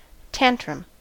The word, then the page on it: tantrum: American English